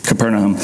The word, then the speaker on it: Capernaum: And a few days after this, he enters Capernaum, which I know that's a weird way to say it, but it's actually like the official pronunciation. It feels weird, but it's Capernaum.